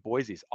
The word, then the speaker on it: Boise: So I think Boise's awesome, and I'm saying it wrong, it's Boise.